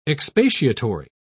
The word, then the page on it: expatiatory: British English